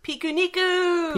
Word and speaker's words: Pikuniku: I've been playing, and I have no idea how to pronounce it, Pikuniku? – Pikuniku!